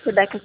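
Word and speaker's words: Rebeka: Rebeca com K.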